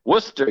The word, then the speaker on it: Worcester: Worcester, yeah. It's spelt a little fucked up.